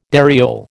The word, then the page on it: dariole: American English